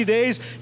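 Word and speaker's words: day (δέ): And is a little Greek word, D-E. Could be pronounced day, and that word literally means, and because of that, as a result of that.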